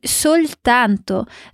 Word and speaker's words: soltanto: Mais uma vez, presta atenção na pronúncia do L em soltanto.